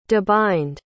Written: debind

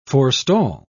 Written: forestall

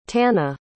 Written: tana

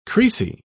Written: creasy

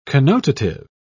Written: connotative

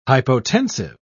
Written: hypotensive

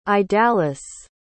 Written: idalis

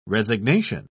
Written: resignation

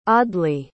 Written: audley

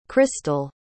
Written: chrystal

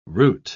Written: root

